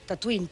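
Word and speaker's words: Tatooine: O sigui, pronunciat es deia igual, Tatuín, però s'escriu amb una lletra diferent.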